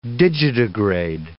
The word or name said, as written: digitigrade